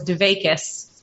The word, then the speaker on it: dvekut: And one of the dvekut, the word that Gabriella used, some of you might know as dvekus, because it's used a little bit more in the Ashkenazi pronunciation.